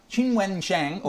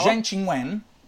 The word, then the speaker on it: Qinwen Zheng: Detta Qinwen Zheng. Bravo. O Zheng Qinwen. Pronuncia ufficiale. – Perfetta.